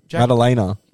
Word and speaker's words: Della Maddalena: No, that's not how you say it. – Jack Della Maddalena. Yeah. – No, Jack Maddalena. – Maddalena. – Oh, is it? – Yeah.